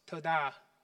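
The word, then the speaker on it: towdah: Another one. T-O-W-D-A-H. I know these have been really confusing to pronounce. This one will give you some relief. It's toda. To-da. Toda.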